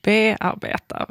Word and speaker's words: bearbeta: Och varför tar du då helt plötsligt betoning i bearbeta? Varför säger vi inte bearbeta?